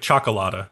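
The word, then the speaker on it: Cioccolata: It's like chocolate, so it's like chocolata.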